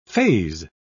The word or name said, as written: faze